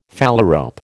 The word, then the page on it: phalarope: American English